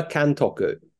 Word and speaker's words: Kantoku: Kantoku.